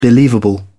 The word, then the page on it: believable: British English